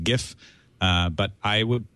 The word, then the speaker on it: GIF: which quotes the specs of the GIF specification. The pronunciation of GIF is in the spec to be GIF, as in Jiffy, rather than GIF, which most people seem to prefer.